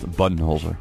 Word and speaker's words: Budenholzer: I've been told it's pronounced Budenholzer.